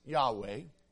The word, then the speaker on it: Yahweh: The name YHWH, probably pronounced Yahweh, is the early form of this same verb. The word Jehovah originated from an attempt to pronounce the consonants YHWH with the vowels from the word Adonai.